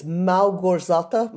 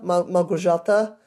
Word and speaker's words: Małgorzata: You don't pronounce the L, it's Małgorzata, Małgorzata Szumowska.